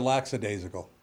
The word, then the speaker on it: lackadaisical: And, yeah, there's no S there. It's just lackadaisical.